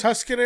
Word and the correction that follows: Tucson: Tuscan? That's not how you pronounce it, is it? – Tucson. – Tucson, there you go.